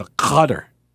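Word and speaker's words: Qatar: Oh, Jay says the country is pronounced gutter. I always found that to be a bit insulting, so I try to go with the correct pronunciation, because you know me, I'm a giver like that. I blend in with my surroundings, you know? So I try to go with a gutter, as I think that's as they pronounce it.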